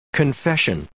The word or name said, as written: confession